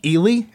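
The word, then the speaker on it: Ealey: Chuck Ely, am I saying that right? – Mm-hmm.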